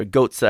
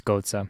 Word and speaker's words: Götze: Or Götze, how would you say it? I think it's Götze. – Götze. – I say Götze, but right.